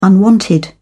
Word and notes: unwanted: British English

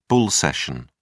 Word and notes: bull session: British English

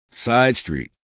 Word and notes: side street: American English

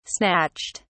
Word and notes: snatched: British English

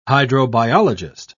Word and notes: hydrobiologist: British English

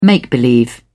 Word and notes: make-believe: British English

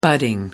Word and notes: budding: American English

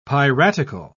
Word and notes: piratical: British English

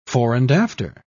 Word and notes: fore-and-after: British English

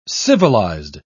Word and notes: civilised: British English

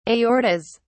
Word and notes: aortas: British English

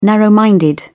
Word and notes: narrow minded: British English

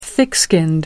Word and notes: thick-skinned: American English

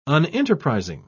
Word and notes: unenterprising: British English

